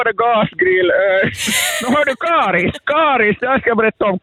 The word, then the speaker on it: Karis: Karis säger jag, det är på mitt uttal.